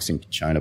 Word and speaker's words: cinchona: So it can be cinchona bark, cinchona bark, cinchona bark. The CH and the C, depending on which country you're in and which vowel it follows, all sort of change.